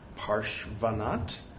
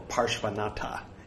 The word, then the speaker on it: Parshvanath: Parshvanath. Porsche. Van. Not. Parshvanath. Or Parshvanatha, if you want to sound like a rube. They don't pronounce the A's.